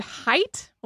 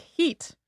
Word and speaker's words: height: Or, to look at it with some other words, the word height, like how tall you are today, would have been pronounced more like heat.